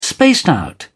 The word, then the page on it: spaced out: British English